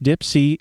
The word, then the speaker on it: DIIPC: The Lunchroom is going to be providing food at Corner Brewery for the D-I-I-P-C, that's pronounced Dipsy, event.